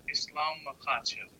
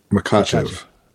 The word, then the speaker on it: Makhachev: Islam Makhachev. – Makhachev. – Okay. Man. Islam Makhachev. Okay. – Makhachev. So for me, I think that the kind of the highlight of the event was Islam Makhachev, who is...